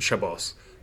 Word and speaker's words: Shabbos: What's the difference between Shabbat and Shabbos? I've heard people say both. – Honestly, both ways are accepted ways of pronouncing the Hebrew word, and both refer to the Sabbath, the day of rest between Friday sunset and Saturday sunset.